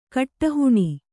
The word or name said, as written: ಗಿಡಹೇನು